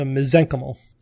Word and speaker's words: mesenchymal: So mesenchymal. When in doubt, that's always my go-to.